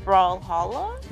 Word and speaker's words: Brawlhalla: Brawlhalla? – Yeah, Brawlhalla.